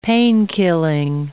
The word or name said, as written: painkilling